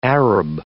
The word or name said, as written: Arab